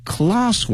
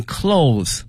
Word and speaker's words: cloth: In addition to avoiding plastic bag, 塑料袋 plastic bag in favor of cloth one, 这里面讲到这个布袋哈，这个布袋我们用这个 cloth。 Cloth, C-L-O-T-H 那么这一次大家千万不能念成 clothes clothes 的话呢，表示衣服的什么什么东西了。